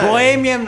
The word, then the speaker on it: Bohemian: Il titolo del film si chiama Bohem Rhapsody, spero che si dice così. Come si dice? – Dica Bohemian lei. Rhapsody. – Bohemian Rhapsody.